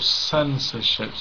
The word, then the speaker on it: censorship: We haven't much time, but you could look at the stress, for example, censorship. So first syllable, we've got suffix here, ship. You could think what other words have this suffix. You could think, here is the schwa, censorship. We don't say censorship, censor, no. We've got unusual consonant sound here because it's a soft C. Censorship, yeah.